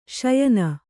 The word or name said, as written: ಶ್ಯೇನ